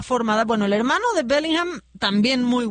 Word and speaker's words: Bellingham: Ya quedamos que es Bellingham.